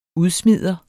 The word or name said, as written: udsmider